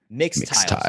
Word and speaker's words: Mixtiles: Mix like a, a mixing bowl. Mix bowls. M I X tiles. – Mix, mix tiles.